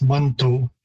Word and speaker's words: Manitou: The word is typically pronounced Manto. So there's a number of different shifts again in terms of pronunciation from the spelling. The OU spelling is primarily obviously in the English and French pronunciation. Manitou or Manitou is one possible variation of the sound, so it's not that far off. I myself and the speakers that I'm most familiar with would say Manitou with an O rather than U,